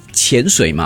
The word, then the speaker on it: 潜: 对，可是一些时候是潜水嘛？ – 对，它是第二声，这是一个第二声字，但是我们很多时候把它念成第三声。